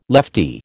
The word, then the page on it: lefty: American English